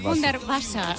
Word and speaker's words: Hundertwasser: No sé si lo he dicho bien, sí, que el alemán bueno, es lo mío. – Me lo han corregido antes, Hundertwasser.